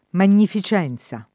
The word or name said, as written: magnificenza